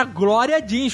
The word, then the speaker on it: Glória Jeans: Não, e o nome não era, era Glória Jeans, como se escreve no Brasil.